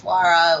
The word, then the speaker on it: Lara: And it wasn't until, you know, my adult years that I started actually correcting people and saying, no, like my name is Lara, like, this is how you pronounce it.